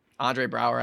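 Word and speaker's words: Andre Braugher: Andre Brower, I think is how you say the actor's name.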